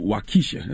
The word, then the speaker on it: Waukesha: Now you got a song on the album called Waukesha.